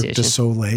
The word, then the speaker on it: Cirque du Soleil: And then we did one of the Cirque de Soleil. I'm going to bastardize that pronunciation. – Cirque du Soleil. Yeah.